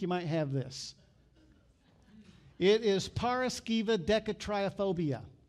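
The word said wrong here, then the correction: paraskevidekatriaphobia: Paraskevi Decatriophobia. Paraskevi Decatriophobia is the fear of Friday the 13th.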